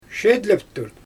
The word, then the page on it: ʃejdleptur: Xyzyl